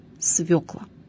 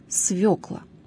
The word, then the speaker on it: свёкла: В современном русском литературном языке, что подтверждается и всеми современными словарями, есть только один, единственно правильный вариант – свекла. То есть вариант с ударением на «ё». Кстати, «ё» в русском языке всегда является ударным. Итак, слово «свёкла», имеющее значение «корнеплод с толстым сладким корнем», произносится с ударением на первом слоге «свёкла».